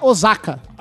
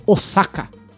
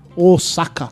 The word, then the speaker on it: Osaka: Só quero dizer que não se pronuncia Osaka. – Ah, é o bairro lá. – Com som de Z, e sim Osaka. Osaka.